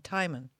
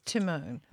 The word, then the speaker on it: Timon: And it's Timon. Everyone's pronouncing it like the other guy, Timon. Never mind. It's Timon.